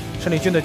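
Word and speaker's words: 谌利军: 胜利军啊，他的名字呢还要跟大家再做一下解释。虽然说报名的时候汉语拼音是陈，但是呢，胜利军的家人包括他本人，都是读胜的这个读音，所以我们一直是叫胜利军。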